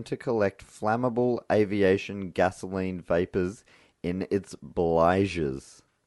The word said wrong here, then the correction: bilges: How do you spell that? – B-L-I-G-E-S. No, no. B-I-L-G-E-S. Bilges.